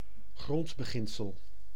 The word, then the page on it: principe: IPA: /pʁɛ̃.sip/; French